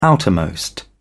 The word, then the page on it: outermost: British English